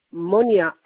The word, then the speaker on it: Monia: Z-A-2-R-A-I. Monia, M-O-N-I-A.